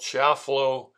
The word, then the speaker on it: Chiafalo: and this became a Supreme Court case called Schiaffalo versus Washington, meaning the state of Washington. And Schiaffalo is spelled capital C-H-I-A-F-A-L-O.